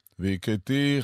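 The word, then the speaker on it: והכיתי: ועברתי בארץ מצרים בלילה הזה, והקיתי כל בכור בארץ מצרים. איך אני יודע? מניין לי שככה יש לבטא? כי סימא טעמי המקרא מופיע על האות תו של ועברתי והקיתי.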